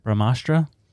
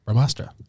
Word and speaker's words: Brahmastra: Brahmastra. All right. – Yeah. Brahmastra.